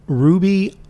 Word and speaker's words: Rouxbe: It's the Ruby Online Culinary School that we signed up for. – And that's spelled R-O-U-X-B-E. – Right, like a roux.